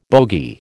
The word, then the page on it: bogie: American English